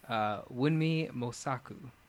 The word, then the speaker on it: Wunmi Mosaku: The actress is Wunmi Mosaku. – How do you say that? – Wunmi. – Wunmi? – Yeah, Wunmi. And then Kasaku. No, I'm sorry, Mosaku. Wunmi Mosaku.